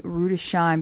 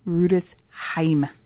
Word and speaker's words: Rüdesheim: Rüdesheim, which many Americans, including me, a few times say Rüdesheim because of the way it's spelled. It's actually Rüdesheim.